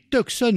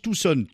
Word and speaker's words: Tucson: Eh bien voilà, on démarre bien, nous sommes à Tucson, bon, parce que ça écrit Tucson, mais on prononce Tucson, Tucson, Arizona, voilà, dans les studios qui sont en plein désert.